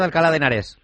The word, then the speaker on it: Alcalá de Henares: Profesor del Departamento de Ciencias de la Computación de la Universidad de Alcalá de Henares. ¿Lo he dicho bien o no? Porque yo esto en mis tiempos no existía, ¿eh? – Sí, está bien dicho.